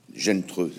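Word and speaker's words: Zheng He: Gentreux, c'est ça. C'est la prononciation. – Mais, euh, qui, se, qui, se, lit Zen e. c'est pour ça que je n'arrivais pas à le prononcer, et qui est un amiral qui aurait pu découvrir le monde si l'empereur de l'époque n'avait l'avait pas arrêté.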